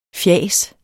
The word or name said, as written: fjas